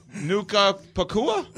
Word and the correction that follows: Puka Nacua: right? Is that not right? – No. – What is it? Well, you're going to tell me what it is? – I mean, listen. – How do you say it? – Puka Nacua? – Puka Nacua? All right, I apologize. Yeah. I got his first and last. I got it backwards there.